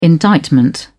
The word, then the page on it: indictment: British English